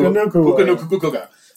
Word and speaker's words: Nacua: I just think, was it Nacua? Did I say that right? Yeah. – Puka Nacua. perfect.